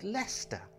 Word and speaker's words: Leicester: I hate to break it to you, because Jester rhymes with Leicester.